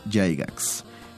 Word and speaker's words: Gygax: Eh, en el caso de Calabozos y Dragones, cada vez que se habla del tema debe salir el nombre de Gary Gygax. Eh, justo como se oye, Gigax se escribe.